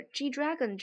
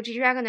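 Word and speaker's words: G-Dragon: OK，啊，然后这个 G Dragon 这个呢。 一直是觉得，如果就是我们作为中国人能用非常标准的英语念出来的这些单词呢，就不要学那些韩国人的韩语发音了，实在是太奇怪了，真的是这样的。就大家就读 G Dragon 就好了，不要去学那个韩语的发音了。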